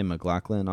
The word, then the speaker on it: McLaughlin: I have Sydney McLaughlin, which is spelled Sydney McLaughlin, but they say McLaughlin on when they're announcing, so I'm going to go with that.